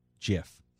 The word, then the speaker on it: GIF: By the way, speaking of GIF, the inventor of the format that is GIF came out and says, actually pronounced GIF. – Yeah, I'm never going to change my ways. – We've all been wrong all these years. An animated GIF. Which makes no sense because it's not like a giraffic. It's a graphic. So it's a hard G.